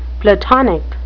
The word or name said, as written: platonic